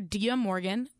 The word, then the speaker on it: Dia Morgan: I'm under Dia Morgan. Dia is spelled D-I-A. Morgan is spelled like it sounds because my real last name is Shamovitz.